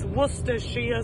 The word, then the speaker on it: Worcestershire: So listening to everybody struggling there with the pronunciation, so it's pronounced Worcestershire sauce. So it's Worcestershire sauce, but say it all together fast, Worcestershire sauce.